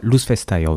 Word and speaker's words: Loufestair: Il s'agit de Loufestair, ça se prononce comme ça ? – Loufestair, oui.